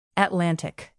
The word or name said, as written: atlantic